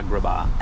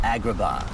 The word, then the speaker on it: Agrabah: Azkaban. Is that how you say it? – Yeah. Agrabah. – Agrabah. – I think you mixed up the Harry Potter Azkaban with Agrabah.